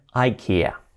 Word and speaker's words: IKEA: Vale, es una marca sueca, que no sé cómo será en sueco, pero en inglés dicen IKEA. IKEA. Es como idea, una idea, pues IKEA. IKEA. I'm going to IKEA to buy some furniture.